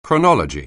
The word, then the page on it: chronology: anglais